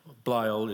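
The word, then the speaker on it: Blædel: Sarah Blaedel, Blyle is how it's pronounced.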